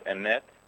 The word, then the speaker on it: emet: Really, the words in Hebrew for truth is emet, with the accent on the first syllable, emet, and the word for falsehood is, I'm sorry, with emet, it's emet, with the accent on the second syllable, emet, and in the other word, it's also a double S sound, but it's sheker, with the accent on the first syllable.